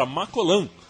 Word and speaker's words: Macolin: E o Brasil então partiu para Macolan perto de Berna para se concentrar. Eu estou assumindo aqui que Macolin não é Macolin, estou falando na pronúncia que eu acredito que seja francesa.